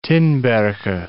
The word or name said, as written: Tinbergen